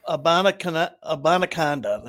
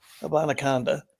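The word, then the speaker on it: Abanikanda: Abanaconda. That's how you say it. Abanaconda.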